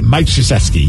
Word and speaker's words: Krzyzewski: How do you say his name? Krzyzewski? – Yeah, Mike Krzyzewski, yes. – Hey, I believe that's correct.